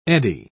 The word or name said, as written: eddy